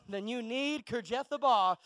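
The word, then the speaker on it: Kiriath-arba: I said it right this time. Kirjathbar.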